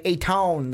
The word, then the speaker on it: Ayton: And then at number 1, DeAndre Ayton, the center from Arizona. I know it's Ayton. I just wanted to say it like Ayton from the Arizona Wildcats.